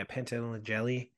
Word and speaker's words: Pantoliano: Joe Pantoliano? – Yeah. – Well, how do you say his name? – Pantoliano or something. – Yeah, Pantoliano.